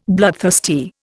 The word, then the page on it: bloodthirsty: Indian